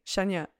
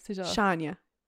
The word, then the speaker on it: Shania: But I never say Shania. I do like Shania. Or Shania.